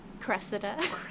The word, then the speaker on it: Cressida: How do you say that? – Cressida. – Sure. – I think I like Cressida. – Cressida, because there was an I in there, right? Because there was an E-I. Cressida.